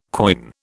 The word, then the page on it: quoin: British English